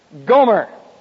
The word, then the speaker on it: Gomer: And he took, and I always pause here, G-O-M-E-R, Gomer. Now, in the Hebrew, it's pronounced Gomer.